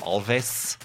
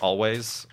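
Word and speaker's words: Alvvays: Recently, Always, although it's the W, instead of a W in Always, it's 2 V's. I kind of want to call them Alves, but it's pronounced Always, is another band.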